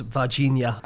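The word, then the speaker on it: Varginha: Although it's probably pronounced Vagina. – Like piranha.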